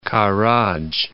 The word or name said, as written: Karaj